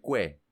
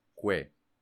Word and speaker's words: Kwai: O sea, Kwai es pipí. Ah. Se dice Kue. Kue.